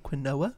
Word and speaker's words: quinoa: Or you ever had anyone pronounce it quinoa? – No. – Really? – It's half right, it's half wrong.